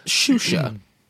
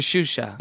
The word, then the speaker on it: Xuxa: Whatever happened to Shusha? – Yeah. – Okay, I'm looking it up right now. According to this, it's spelled XUXA. Who knew? – Oh. – Shusha, I guess, was a Brazilian children's television host known for her revealing outfits.